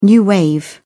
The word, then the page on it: new wave: British English